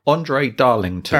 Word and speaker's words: André Darlington: Andre Darlington.